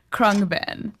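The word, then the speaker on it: Khruangbin: Krungbin.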